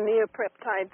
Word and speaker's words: neopeptides: Correct me if I'm wrong with the pronunciation. Is this the neopeptides you said? – Yes, it's called the neopeptides within the hypothalamus.